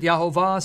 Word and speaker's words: Yahweh: In Hebrew, Yod, Hey, Vav, Hey, where we get the divine name. Some pronounce it Yahovah, some Jehovah, Yahweh, however you want to pronounce it.